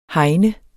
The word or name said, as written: hegn